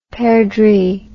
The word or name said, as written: perdrix